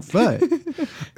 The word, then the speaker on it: Bigfoot: I need to hunt that Bigfoot. – Yes. Oh, everybody should know the emphasis in Bigfoot is on the foot. – Is on foot. – Bigfoot.